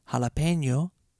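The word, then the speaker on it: jalapeño: Or if you're going to say it correctly, jalapeno poppers.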